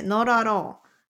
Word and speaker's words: not at all: あー、こう、音がね、これ抜け落ちるんだよね。ノーラロー。